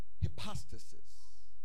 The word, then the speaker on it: hypostasis: Now, the word substance in the Bible here, that word, Howard, is hypostasis. Hypostasis. H Y P O S T A S I S. Some people pronounce it hypostasis. Hypostasis or hypostasis.